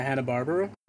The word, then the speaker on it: Hanna-Barbera: The Hanna-Barbera? – Yes. – That's how it's pronounced.